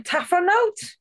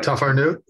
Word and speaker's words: tafarnout: What's special about, how am I pronouncing that? – I'm going to go tafarnout. – Is that good? – That sounds good. Yep, tafarnout. Yep.